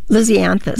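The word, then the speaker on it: Lisianthus: This, the flower that Lysanthus? Oh, that's the one you're supposed to start in January, I know. – Lysianthus. – Yes, yes, I said it wrong.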